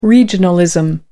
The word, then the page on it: regionalism: American English